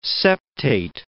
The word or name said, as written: septate